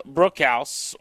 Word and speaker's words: Brookhouse: That's Sam Brookhouse with us on Grant and Danny. You can follow him on Twitter. It's his last name is spelled Bruchhaus, and it's Brookhouse Sports.